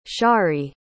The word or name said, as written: sharry